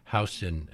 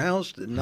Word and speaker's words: Houston: One of the great mysteries of New York for many people is how H-O-U-S-T-O-N is pronounced Houston and not Houston.